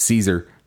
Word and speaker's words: Cesare: I heard him on the horror show with Brian Keene, and he said that he says it's Caesar, even though everyone else says it's Cesare.